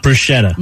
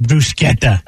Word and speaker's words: bruschetta: Not bruschetta. – No, we're saying it wrong. Mm-hmm. – She's clowning us. You know it's bruschetta, right? Yeah. I'm going to tell the Americans that it's pronounced bruschetta.